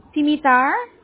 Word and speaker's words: Dimitar: My name is Dimitar. D-I-M-M-I-T-A-R. – I'm sorry. How do you spell that? – It is D-I-M-I-T-A-R. – Dimitar? – Dimitar, yes.